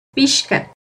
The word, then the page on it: pixca: Náhuatl